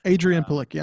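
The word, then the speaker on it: Adrianne Palicki: Adrian Palicki. I'm pretty sure that's how you say it.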